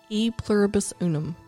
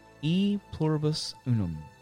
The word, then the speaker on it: E pluribus unum: E pluribus unum. – E pluribus unum.